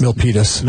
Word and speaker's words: Milpitas: There's Mountain View, Mill Pilatus. – Cupertino. – Am I saying that right? Milpitas. – Milpitas.